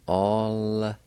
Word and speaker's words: alitsadodi: And in this word, the I with the L will be silent. You need to pronounce the L sound with the A like this. All-za-do-ti. All-za-do-ti. All-za-do-ti.